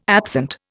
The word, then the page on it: absent: Indian